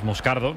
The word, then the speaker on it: Moscardo: Como bien ha pronunciado Unai, es Moscardo, es brasileño, sí, sí.